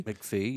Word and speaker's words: MacFie: On defense, Carter, M-A-C-F-I-E. How would you say that? – M-A-C-F-I-E? – McPhee? – McPhee, yeah.